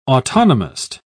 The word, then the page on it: autonomist: British English